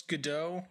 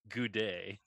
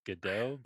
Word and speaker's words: Godot: How did he pronounce Godot? – He pronounces it Goudet, which is not even a little correct.